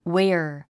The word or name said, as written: ware